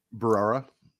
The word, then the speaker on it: Barrera: What is basically Melissa, I don't remember how to pronounce her last name. – Barrera. Yeah.